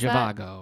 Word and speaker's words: Zhivago: Dr. Zivago, is that how we pronounce Zhivago. that? Zivago. Zivago. Yes.